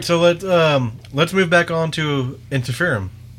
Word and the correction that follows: Ensiferum: Did I say it correctly? Yes. Yeah. – Maybe. – Yeah. How do you say it again? – Ensiferum.